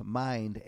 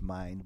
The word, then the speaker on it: Mynd: And a quick word on the Undershare track is actually pronounced Mind, M-Y-N-D. It's actually pronounced Mind.